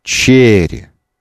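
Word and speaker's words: Chery: То есть сам бренд произносится Cherry... Вообще у нас в языке в слове должно быть одно ударение. Cherry. Cherry, так называется бренд.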